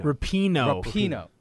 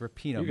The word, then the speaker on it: Rapinoe: Number two, I'm probably going to butcher her name, but I think it's Megan Rapinoe. – Rapinoe. – Okay, it's Rapinoe. Megan Rapinoe.